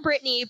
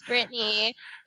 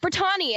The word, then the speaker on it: Brittani: Like, it's regular Brittany, but with an I. And it throws off so many people. Like, I never knew that until I got into college and professors are trying to pronounce my name. And they're like, Brittani. And I'm like, it's Brittany. And then people call me like, Brittani. And I'm like, bitch, it's Brittany.